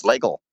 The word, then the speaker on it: Slagle: Who very kindly provided a spelling pronunciation. It's Slagle.